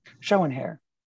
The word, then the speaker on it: Schoenherr: Schoenherr.